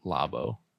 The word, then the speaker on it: Labo: This, and I'm probably going to pronounce it wrong. – Yes. – Nintendo Labo? – Yeah, Labo. Okay. What did you used to call it before? Labo? – I said Labo.